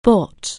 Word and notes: buy: anglais

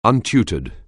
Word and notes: untutored: anglais

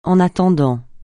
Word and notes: en attendant: francais

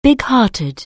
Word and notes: bighearted: anglais

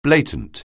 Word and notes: blatant: anglais

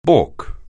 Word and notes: balk: anglais

